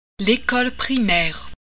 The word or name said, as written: primaire